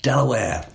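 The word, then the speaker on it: De La Warr: So on the page, it's D-E-L-A-W-A-R-R. – Ah, right. – But Delaware is how they pronounce it.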